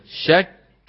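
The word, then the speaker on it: شكّ: شك بدك تلفظ كم كاف؟ شك، شك.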